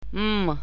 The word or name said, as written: m